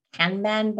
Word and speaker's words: Kanban: Some of them that my students really like, Miro, which is, you know, a bit of a whiteboard, a bit of a Kanban board. I can't even say that right. – I can only say it if I change my accent. A Kanban.